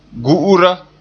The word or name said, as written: gu'ura